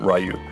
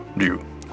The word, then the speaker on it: Ryu: And so, like, unless you speak to somebody who speaks Japanese or you listen to an example, I don't think anybody would think of pronouncing R-Y-U as Ryu. – Oh. – They'd always say Ryu. Because that's the way it would be pronounced.